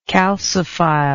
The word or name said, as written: calciphilous